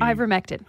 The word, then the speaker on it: ivermectin: Talking about, again, the how do you pronounce it? I should know this, but the ivermectin. – Ivermectin, thank you, that people are actually trying to take for COVID, which is a horse dewormer.